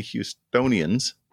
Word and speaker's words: Houstonians: This story begins in the mid-1980s when some big city Houstonians, is that how you say it? Houstonians? – Yeah, Houston. – Houstonians? – Sure.